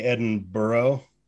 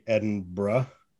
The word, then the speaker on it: Edinburgh: You don't say Edinburgh, you say Edinburgh.